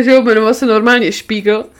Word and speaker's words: Spiegel: Pan Charles Spiegel. – Spiegel. A on se byl pravděpodobně byl německýho původu a jmenoval se nomáje Spiegel, což znamená zrcadlo. A američani to neumějí vyslovit správně a říkají mu Spiegel.